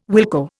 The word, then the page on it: wilco: Indian